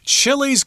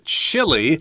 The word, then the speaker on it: Chile: Now, in South America, we've got this country called Chile. – Mm -hmm. that's how we pronounce it in English, but if you're speaking their native language of Spanish, you'd say Chile.